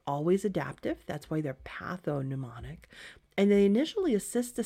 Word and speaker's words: pathognomonic: So I pronounce this one as, the world pronounces this one as pathognomonic changes.